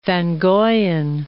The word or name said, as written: van Goijen